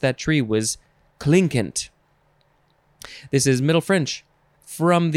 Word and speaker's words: Clinquant: Next is, you could pronounce this a few ways, clinkant, or clonkant, but it's spelled Clinquant. C-L-I-N-Q-U-A-N-T. I think I'll just try and say clinkant.